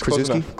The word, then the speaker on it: Krzyzewski: Mike Krzyzewski. I think is that how you pronounce it? – It's Krzyzewski? Close enough, yeah. – Okay, Krzyzewski.